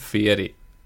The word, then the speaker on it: Fieri: You got to pronounce it Fieri.